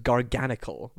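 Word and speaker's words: Garganacl: Well, I believe it's pronounced garganacle.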